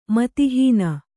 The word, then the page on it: ಮತಿಹೀನೆ: kannada